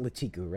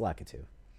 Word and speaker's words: Lakitu: Lakitu, or it's not Latiku, right? It's Lakitu.